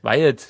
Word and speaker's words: Wyatt: Wyatt, genau.